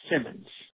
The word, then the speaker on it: Simmons: It's Steve Simmons. – Okay. Can you spell that for me? – S-T-E-V-E. Last name is Simmons. S-I-M-M-O-N-S.